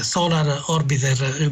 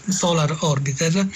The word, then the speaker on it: Solar Orbiter: Bene, eh, Mauro Masserotti, eh, noi abbiamo già avuto come dire, eh, i, i primi sintomi del fatto che eh, Solar Orbiter, Marco da Torino giustamente mi fa notare che eh, la mia pronuncia è stata del tutto improbabile, quindi Solar Orbiter,